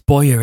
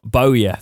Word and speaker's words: Bowyer: After being deemed surplus to requirements by Birmingham boss Lee Bower, it's Bowyer, isn't it? I can always say that wrong. Bowyer, thank you.